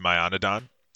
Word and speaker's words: Mayanadon: Well, it's actually pronounced Myanodon. – That's your fault for putting A's in it. – Well, that, you know, it wasn't, it's my Myanodon.